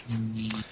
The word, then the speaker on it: Kopimi: It's spelled K-O-P-I-M-I. So that's why we were having the wondering about how it's being pronounced. Copy meism. – Yes. Copy-me.